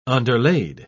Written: underlaid

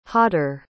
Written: hotter

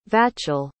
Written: vachel

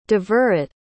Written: devorit

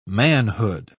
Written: manhood

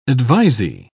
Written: advisee

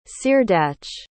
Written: ceardach

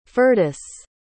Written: Firdus

